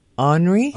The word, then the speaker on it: Henri: Well, H-E-N-R-I in America. – Is Henry. – Is Henry. – Yeah, but they're pronouncing it Henri. – Of course they are. – You know, they have to be exotic. – Yes. – And it's still forecast. – I haven't heard anybody call it Henri. – That's the correct pronunciation.